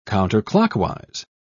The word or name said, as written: counterclockwise